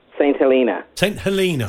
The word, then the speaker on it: St Helena: Let's establish how we pronounce it. Is it Helena, Helena, Helena? What is it? – St Helena. – Helena. Helena, right.